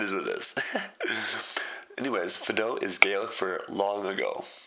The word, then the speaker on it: Fado: Our next stop is Fido, F-A-D-O. Pronounced F-apostrophe-D-O-E. I know this because I asked the waitress directly. I said, is it Fado's or Fado? She goes, Fado.